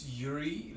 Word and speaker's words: Yuriy: My name is Yuri Ledvinsky, and it is spelled Y-U-R-I-Y. Starts with the Y, ends with the Y. Last name Ledvinsky is spelled L-I-T-V-I-N-S-K-I.